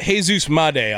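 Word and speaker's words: Jesús Made: Jesus Madé. I'll stop saying the whitest pronunciation ever to his name. Thank you, both of you, on that.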